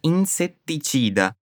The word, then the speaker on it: insetticida: Davanti a un insetticida. Perceba a pronúncia da palavra insetticida, com dois T's e o T italiano.